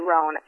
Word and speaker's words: Rouen: It's called a roan. Oh, it's spelled R-O-U-E-N, but it's a roan, and it basically looks like a big mallard.